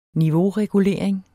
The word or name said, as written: niveauregulering